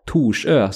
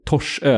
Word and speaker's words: Torsö: Så har ni inte varit på Torsö, eller vi hade också lite Stockholmsbesök som bodde faktiskt på ett boende där ute som sa Torsö, så gå inte den vägen. Torsö är rätt uttalat, men det är väldigt fint, så det kan jag rekommendera.